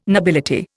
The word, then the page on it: nobility: Indian